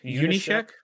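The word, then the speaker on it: Junyszek: 343 community manager John, how do you say his last name? I can never say it. Check. What's that? – Unishek. – Yeah. John Unishek. Yeah.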